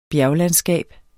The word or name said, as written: bjerglandskab